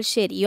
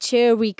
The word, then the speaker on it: Cherry: Și am zis, mamă, Nicole Sherry. Eu atunci nu știam exact Cherry, că de fapt Asta, asta ar fi pronunția, că eu aveam, uh, știi cum, uh, în capul meu erau roșiile alea sherry, știi?